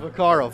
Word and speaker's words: Vaccaro: Is it Kenny Vaccaro or Kenny Vaccaro? – The first one, Vaccaro. – Vaccaro, very good.